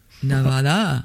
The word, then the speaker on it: Nevada: Nevada. – Nevada. That's the correct pronunciation. That's the way the locals say.